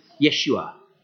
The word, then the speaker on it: Yeshua: That's how Jesus' name would have been pronounced in Israel Yeshua, meaning Saviour.